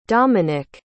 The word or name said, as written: dominick